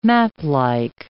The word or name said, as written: maplike